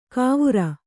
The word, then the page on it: ಕಾವುರ: kannada